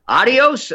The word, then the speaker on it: Adios: I guess the right way to pronounce that would be Adios America, in August of 2015, her book came out.